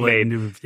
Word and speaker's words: Villeneuve: Villeneuve, yeah.